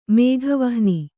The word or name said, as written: ಮೇಘವಾಹನ